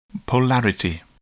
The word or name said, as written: polarity